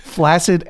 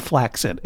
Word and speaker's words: flaccid: Flaccid and flaccid. If someone said flaccid, I would think they were trying to say flaxseed.